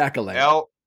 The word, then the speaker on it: Acolyte: It's the Acolyte, the Alsolite. Acolyte? – The Acolyte. – Acolyte, like Aka-Seltzer.